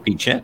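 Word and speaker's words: Phichit (พิจิตร): This year, even with COVID-19, we've shared the gospel in every village in, how do you pronounce it, Phichit? – Phichit.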